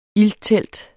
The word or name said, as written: ilttelt